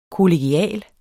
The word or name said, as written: kollegial